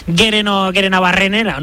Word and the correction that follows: Gerenabarrena: ¿no? – Bueno, pues casi. – Gerenabarrena. – Ahí está.